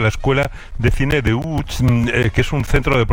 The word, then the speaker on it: Łódź: Pues vamos entonces a Łódź, si te parece, para saber algo más de esta ciudad, aunque se pronuncia Łódź, se escribe Lodz, por si alguien lo busca. – Lodz, sí, Google, sí, no, casi no tiene nada que ver la pronunciación.